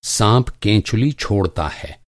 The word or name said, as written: साँप